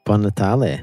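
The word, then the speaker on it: Buon Natale: Bon Natale. – Bon Natale.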